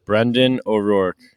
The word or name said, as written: O'Rourke